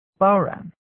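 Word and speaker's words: bodhrán: A bodhran, which is pronounced bauran.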